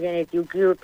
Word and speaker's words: Gene2Cure: Bueno, quiero también preguntarte, porque paralelamente continúa la investigación impulsada, a ver si lo digo bien, por Gene2Cure. – Sí, en inglés, Gene2Cure, pero sí, vale. Lo has dicho perfectamente.